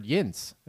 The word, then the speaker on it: yinz: A yinzer is a term that denotes someone from Pittsburgh. It derives from the word yinz.